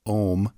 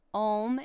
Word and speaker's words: Homme: They're called Ohm, but it's spelled H-O-M-M-E, not O-H-M, as you might think. You've just heard it out of context. – And so Ohm is a duo from Chicago, like I mentioned.